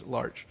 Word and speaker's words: L'Arche: Jean Vanier started a community called L'Arche, or L'Arche, but proper pronunciation is L'Arche.